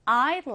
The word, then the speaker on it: I'd: 줄여서 쓰면 apostrophe d like to 예를 들어 I would like to 해주린 말은 I'd like to 이렇게 D를 약하게 발음해주시면 됩니다.